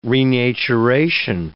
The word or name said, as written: renaturation